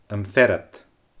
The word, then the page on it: Am Fear Ud: Scottish Gaelic